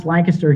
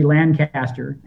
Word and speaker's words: Lancaster: It is pronounced Lancaster here, which is not the same as its English brethren, which would be Lancaster, and which is most of the pronunciations in the United States, New York, California, many others.